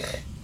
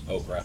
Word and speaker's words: ochre: How do you pronounce the word O-C-H-R-E? – O-C-H. OSHA? – R-E? – O-C-H-R-E. – Ocker? – Ocker. – Ocker? – Yeah, Ocker. – I've only ever heard that word pronounced as okra. – Oh, no. Yeah, fried okra? Oh. – No, O-C-H-R-E.